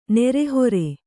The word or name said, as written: ನಿರಾಹಾರ